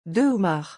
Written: deux homards

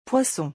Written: poissons

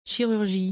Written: chirurgie

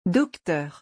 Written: docteur

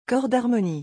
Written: cor d'harmonie

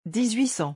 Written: 1800

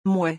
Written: mouais

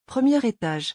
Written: premier etage

